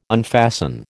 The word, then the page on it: unfasten: American English